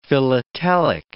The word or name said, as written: philatelic